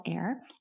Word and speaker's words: Aer: I call it the clit sucker, but it's spelled AER. It's called Air.